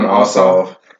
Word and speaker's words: Ossoff: You know, shout out to John Ossoff. What's how you say it? I always say that shit wrong. – John Ossoff.